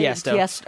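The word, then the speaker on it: Tiësto: Hot In It by, how do you say Tiesto.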